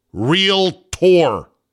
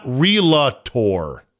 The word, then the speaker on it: Realtor: Realtor is two syllables. Realtor. Not Relator.